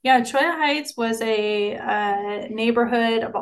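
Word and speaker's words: Cholla: So maybe you could talk about, is it pronounced Chola Heights on Chaya Heights? Yeah. I know you're not a local when you pronounce it.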